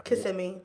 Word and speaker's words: Kissimmee: What, Kissimmee? That's how you say it? – Kissimmee.